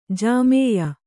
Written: ಜಾಮೇಯ

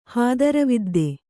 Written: ಹಾದರವಿದ್ದೆ

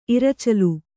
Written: ಇರಚಲು

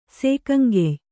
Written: ಸೇಕಂಗೆಯ್

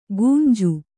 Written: ಕುಂಜು

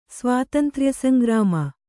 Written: ಸ್ವಾತಂತ್ರ್ಯಸಂಗ್ರಾಮ

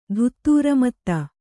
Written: ಧುತ್ತೂರಮತ್ತ

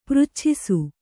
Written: ಪೃಚ್ಛಿಸು

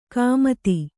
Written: ಗುಮ್ಮತಿ

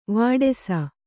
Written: ವೇಡಿಸು

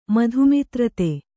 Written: ಮಧುಮಿತ್ರತೆ

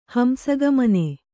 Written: ಹಂಸಗಮನೆ

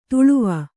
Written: ತಳಿವು

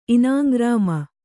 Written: ಇನಾಂಗ್ರಾಮ

